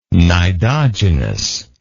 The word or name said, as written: cnidogenous